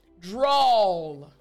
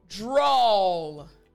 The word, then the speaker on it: drawl: You drop your chin, you drop your jaw. That's how you make the sound properly. Again, after me, aw. Excellent. Now remember the L real quick, the L sound at the end of the word. Remember to make the L sound properly. All you're going to do is make sure your tongue touches the roof of your mouth. So L. Excellent. So together, all. Excellent. Now after me, the entire word. Here we go. After me, drawl. Excellent. Last time, drawl.